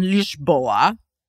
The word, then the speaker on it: Lisboa: Okay, so I went to Portugal and I was in Lisboa, which was the first time I knew that that is how you pronounce it.